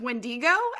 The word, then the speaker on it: Wendigo: yeah, that's pronounced Wendigo, actually.